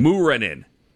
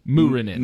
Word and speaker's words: Muurinen: Capital M-E-E-K dash K-A-H. Mika. – Muurinen. – Here's how Muurinen is spelled. Capital M-O-O, as in moo, like a cow, dash R-E-H dash N-E-N.